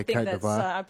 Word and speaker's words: Côte d'Ivoire: I don't know how to pronounce it, like the real French way. Devoir? Côte d'Ivoire? – Yeah, Côte d'Ivoire.